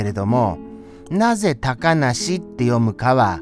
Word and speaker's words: 小鳥遊: そして、あのー、小鳥、あ、小鳥ですから、小さいの次鳥でした鳥は鳥です普通のはいその小鳥遊ぶと書いて高梨って読む